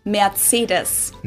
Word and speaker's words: Mercedes: So most Americans call this Mercedes. In German, we pronounce it Mercedes. – What? Mercedes? – So Mercedes versus Mercedes. – No, it's Mercedes. Mercedes. Mercedes-Benz. Mercedes-Benz.